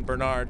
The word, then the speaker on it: Bernard: He suggested we should name the van Bernard. – Bernard? – Well, I know. Yeah, I think we should probably use the American pronunciation, Bernard.